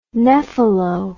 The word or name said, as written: nephelo